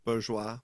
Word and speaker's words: Bourgeois: And the French Settlement coach, Jake, whether it's Bourgeois or Bourgeois. – Bourgeois. – Yeah, exactly. Some people bourgeois. Like to pronounce it French, and some people don't. So bourgeois.